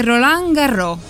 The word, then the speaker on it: Roland Garros: Il 30 maggio Nicola Pietrangeli vince Roland Garros, giusto? Ho detto bene? – Sì, è Roland primo...